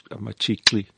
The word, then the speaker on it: Machikli: Aubrey Machikli, I'm trying very hard to pronounce that. No, you got that right, spot on. – Machikli, that's the right one.